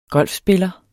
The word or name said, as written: golfspiller